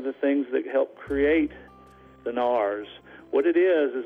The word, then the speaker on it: Narrows: It's called or spelled the Narrows, N-A-R-R-O-W-S, but the local pronunciation is the Nars, more like N-A-R-S.